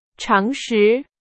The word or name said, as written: 常识